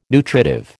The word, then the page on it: nutritive: American English